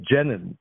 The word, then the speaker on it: Jenin: And by the way, Rick, it's actually not pronounced by the Arabs Jenin, but Jenin.